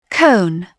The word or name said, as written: cone